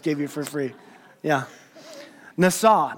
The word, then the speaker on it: nasa: The Hebrew word here is the word nasa. Now, nasa, it's spelled N-A-S-A, NASA, right?